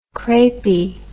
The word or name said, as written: crepey